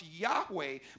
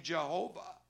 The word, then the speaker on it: Yahweh: the name by which he went was we now pronounce Yahweh, but for many, many, many, many years, people thought that the Hebrew pronunciation was Jehovah. How many ever heard the name Jehovah? Well, there's no such Hebrew word. The, the Hebrew doesn't even have a J sound.